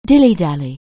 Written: dilly-dally

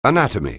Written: anatomy

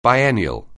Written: biennial

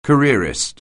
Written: careerist

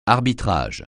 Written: arbitrage

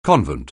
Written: convent

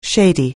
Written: shady

